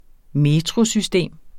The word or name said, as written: metrosystem